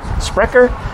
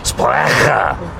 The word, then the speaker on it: Sprecher: Yeah, how do you pronounce that? – Sounds delicious. – Sprecher. – And I was saying Sprecher. – You can say it that German.